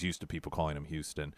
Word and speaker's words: Hewson: Should you approach Houston and say his name, people oftentimes think it's Houston, like Houston, Texas. That isn't his name. It's Houston, H-E-W-S-O-N.